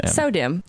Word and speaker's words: SO-DIMM: For audio listeners, I'm holding up a piece of paper that says S-O-D-I-M-M. – Sodim, right? So yeah. Everyone pronounces it Sodim, but they spelled it, the pronunciation in this book, as S-E-W-D-I-M-M. And I was like, well, yeah, right? So if you actually think about the word so as in sewing, like that's correct. Mm-hmm.